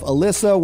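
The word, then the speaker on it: Ellisa: Make sure you follow my beautiful wife, Alyssa. What's your handle? It's Alyssa but it's spelled weird. It's E L L I S A, Allenton.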